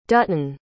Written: dutton

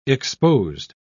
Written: exposed

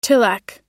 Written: tilak

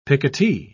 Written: picotee

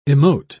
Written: emote

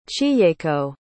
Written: chiyeko

